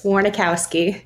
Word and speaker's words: Warnikowski: Yeah, I say my name's so long that it can get kind of confusing, but how it looks is how it's said. So it's just Warnikowski.